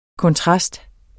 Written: KONTRASTER

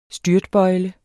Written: styrtbøjle

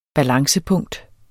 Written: balancepunkt

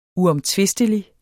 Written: uomtvistelig

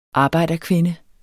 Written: arbejderkvinde

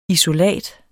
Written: isolat

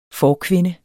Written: forkvinde

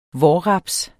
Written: vårraps

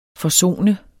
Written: FORSONEDES

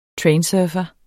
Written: trainsurfer

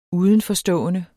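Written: UDENFORSTÅENDES

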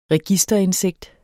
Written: registerindsigt

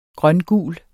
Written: grøngul

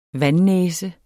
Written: vandnæse

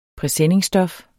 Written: presenningstof

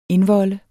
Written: indvolde

